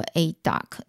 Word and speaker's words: Aidoc: 那另外第四间是这个佳能，第五间是这个 ADOC，它是英文是 AIDOC。那我看到这间公司的时候，哎，其实我我真的不知道他们在做什么的。然后我上网去查了一下呢，这个 AIDOC 它发音做 ADOC，它是一间以色列的公司。